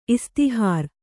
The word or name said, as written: ಇಸ್ತಿಹಾರ್